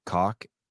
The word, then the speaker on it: caulk: I don't ever pronounce the R in caulk. It's cock. And what's the other word that I don't pronounce? Duct tape, D-U-C-K.